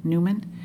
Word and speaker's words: Newman: Yes, this is Linda Newman, and my last name is spelled N-E-W-M-A-N.